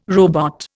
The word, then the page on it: robot: Indian